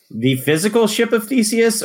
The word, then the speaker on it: Theseus: I don't actually know how to pronounce Theseus. Theseus. Tell us about this. Said it right the first time.